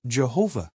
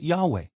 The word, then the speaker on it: Jehovah: In English, it is usually pronounced Jehovah, but some people pronounce it Yahweh.